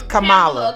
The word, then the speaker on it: Kamala: We're going to talk about Miss Kamala Harris, if that's how y'all want to pronounce it. It's Kamala. – Kamala. I say Kamala. – It's Kamala to us. Yeah. So y'all already know. – I got a cousin named Tamala. She spelled the same way, so I guess Kamala.